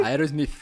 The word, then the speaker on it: Aerosmith: Aerosmith.